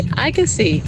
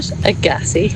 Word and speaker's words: Agassi: It's Andre Agassi, not Agassi. – Agassi, not Agassi. You remind me of Hermione. – It's Leviosa, not Leviosa. – Oh, now there you go. Sorry to Agassi.